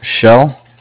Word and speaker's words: Shell: Shell. – How do you spell that, please? – S-H-E-L-L.